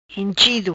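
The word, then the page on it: Enchido: Patua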